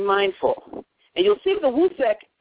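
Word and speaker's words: Usekh: Wusek, Wusekh, or just U-S as in Sam, E-K-H, Wusek. The U is pronounced like a W sound, Wusek.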